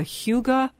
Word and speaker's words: hygge: Here we have the authors of the Huga Life, and it's spelled H Y G G E.